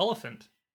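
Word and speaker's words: Olyphant: If you're unfamiliar, you have Sheila, played by Drew Barrymore, and her husband Joel, played by Timothy Oliphant. Yep. I can never say his name. Is that right? – I think it's Oliphant.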